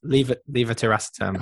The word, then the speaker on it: levetiracetam: Am I saying it right? – Yeah, I would have said it. Levetiracetam. – Oh, levetiracetam. Ah, you know what? Still to this day, I've been a doctor for God knows how long, and I still can't say it right.